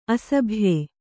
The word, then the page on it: ಅಸಭ್ಯ: asabhya